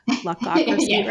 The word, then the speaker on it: Luckocracy: Luckocracy? The word luck? Luckocracy, right? – Yes. – Make sure I said it right.